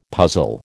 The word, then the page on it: puzzle: American English